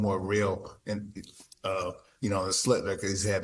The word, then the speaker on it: Schlittler: I've seen probably Skeens pitch twice on TV, and I've seen, how you say his name, Schlittler? – Yeah, Schlittler. Yeah, yeah, I've one.